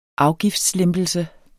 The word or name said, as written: afgiftslempelse